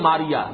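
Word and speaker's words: ماریہ: ماریا، یہ میری در حقیقت ماریا کہلاتی ہے، اس لیے کہ عربی میں یہ تلفظ ماریا ہے اور وہ انگریزی میں میری ہے،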